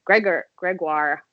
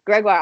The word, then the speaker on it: Gregoire: and like Erin Gregoire, sorry, I always say it wrong, Gregoire on our team, who qualified for the trials but wasn't able to run.